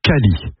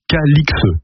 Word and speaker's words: Calix: J'ouvre ici une parenthèse, le nom était autrefois prononcé Cali, comme le montrent diverses formes anciennes du XIIIe au XVIIIe siècle, mais l'orthographe aberrante Calix a entraîné la prononciation actuelle Calix. Voilà pourquoi nous prononçons Calix depuis plus de 300 ans.